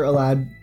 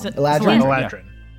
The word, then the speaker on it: Eladrin: Yes, and I am a winter Eladrin. I keep pronouncing it wrong. – Eladrin.